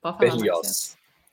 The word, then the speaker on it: Berlioz: Berlioz.